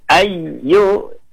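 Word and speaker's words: ayyu (أَيُّ): Tidak boleh ayyu, tapi ayyu harus dihentakkan sedikit, disebut nabar.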